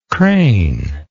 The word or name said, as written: cranely